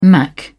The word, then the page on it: mac: British English